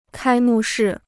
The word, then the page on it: 开幕式: kāi mù shì